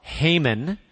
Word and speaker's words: Heijmen: I'm the one who you look down and you're like, how would you possibly pronounce that name? H-E-I-J-M-E-N. So once and for all, it's Haman.